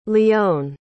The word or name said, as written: lyon